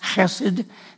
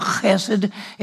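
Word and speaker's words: chesed (חסד): It's the word H-E-S-E-D. It's pronounced chesed. It's got that rough guttural in Hebrew, chesed.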